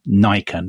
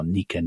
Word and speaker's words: Nika: And so the crowd start chanting at him as they go berserk and they start chanting as one, famously, Nika, Nika, or Nika, Nika, depending on your pronunciation, which basically means victory, victory, or conquer, conquer, which is, I guess, which is what they would have chanted anyway.